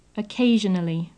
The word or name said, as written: occasionally